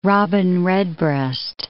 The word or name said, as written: robin redbreast